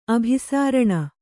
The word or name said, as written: ಅಭಿಸರಣ